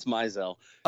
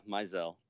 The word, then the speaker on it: Mizell: And yeah, Mizell. Very common. – It's common. It's common to do that, but my last name people don't really screw up because it's pretty phonetically sound. But like I've heard people whose last names are spelled like S-M-I-T-H.